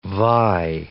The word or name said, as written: vie